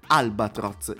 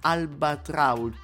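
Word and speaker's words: Albatraoz: E lui, e lui ed è lei con questa meravigliosa base, ovvero quella che ci dice così: I'm an albatross. Io così la pronuncio, ma in realtà è I'm an albatrauch.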